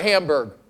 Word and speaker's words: Hamburg: Brother Sheetal, his purpose is, how do you say that? Hamburger? Hamburg? Sorry. Food, whatever. Hamburg or Hamburg? – Hamburg. – Hamburg. We're not German, we're American. Hamburg. Okay, fine, Pennsylvania.